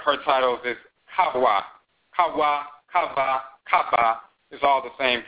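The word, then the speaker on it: Khavah: They have the consonantal structure, but they pronounce it Kava. And then in English, it becomes E, but it's Kava.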